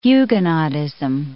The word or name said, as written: Huguenotism